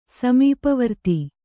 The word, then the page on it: ಸಮೀಪವರ್ತಿ: kannada